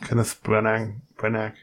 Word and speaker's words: Kenneth Branagh: Kenneth Branagh, ja.